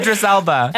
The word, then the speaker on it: Idris Elba: How do you say it? Elvis Elba? – Idris Elba.